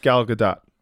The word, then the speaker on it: Gal Gadot: So what I was saying, Gal Gadot, first of all, that is how it is pronounced, Vince. It's pronounced Gal Gadot.